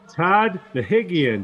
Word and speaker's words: Nahigian: And today we're joined by CROYA manager Todd Nahigian.